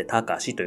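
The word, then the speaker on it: 高史: で、卓球の卓電卓の卓という字に歴史の史という字を書きまして、高しと読むんですけれども、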